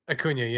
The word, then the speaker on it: Acuña: Daniel Acuña, is that how you say his name? – Acuña, yeah.